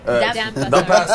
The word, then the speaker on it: Dampa: It's called Dump. – No, it's not. – Dampa. – I say it wrong.